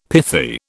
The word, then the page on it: pithy: British English